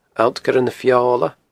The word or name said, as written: Allt Coire na Feola